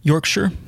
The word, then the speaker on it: Yorkshire: We get to see a scenery of a countryside, which I believe is Yorkshire. Is that how you say it? – Yorkshire? – Yorkshire.